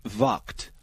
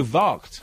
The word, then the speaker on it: Vaakt: Harjinder says the movie in question is pronounced Vakt. – Oh, Vakt. – As in V-A-A-K-T.